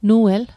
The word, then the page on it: Nuel: Mi'gmaq